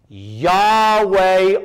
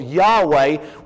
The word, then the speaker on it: Yahweh: That is your English translation telling you that it is a translation of the Hebrew word, which we think is pronounced something along the lines of Yahweh, which is the very name of God.